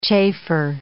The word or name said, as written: chafer